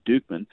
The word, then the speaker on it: Dukeman: And it was brought to you by the Dukeman Family Winery, founded in 2004 by Dr. Lisa and Stan Dukeman, looks like Dutchman, but it's pronounced Dukeman, in a quest to bring world-class winemaking to Central Texas and the Texas Hill Country.